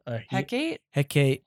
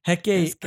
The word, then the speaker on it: Hecate: I mean, my big thing is the design of the, how do you say her name? Hectic? – Hecate. – Why can't I say words? – I don't know. That one. – Hecate, her disintegration and then into the squid snakes is just beautiful.